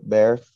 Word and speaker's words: Bexar: b-e-x-a-r bexar bear the x is silent